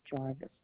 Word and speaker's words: Jarvis: My name is Sharon, C-H-E-R-Y-N, and my last name is Jarvis, J-A-R-V-I-S. – Okay, thank you so much for that information. Last name is spelled J-O-N-J-O-H-N-S-O-N.